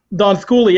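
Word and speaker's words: Donskoi: Donskoy, yeah.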